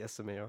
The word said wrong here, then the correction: ASMR: Or ASMR.